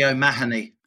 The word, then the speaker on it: O'Mahony: It's probably O'Mahony. – O'Mahony, sorry. – Yeah, that's generally how O'Mahony.